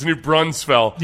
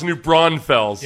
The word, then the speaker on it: New Braunfels: It's New Braunfels. – Yeah. – Well, meanwhile, the people in New Braunfels will tell you, no, it's pronounced New Braunfels.